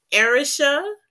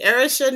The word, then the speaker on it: Arisha: All right, so I have a special guest with us today, Arisha. Did I say that right? – Yes, ma'am. – Okay, perfect. Arisha Nabors.